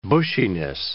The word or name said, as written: bushiness